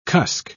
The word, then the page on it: cusk: British English